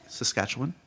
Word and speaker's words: Saskatchewan: Saskatchewan.